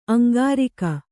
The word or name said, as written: ಅಂಗರಕ್ಕೆ